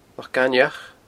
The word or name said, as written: Loch Gainmheach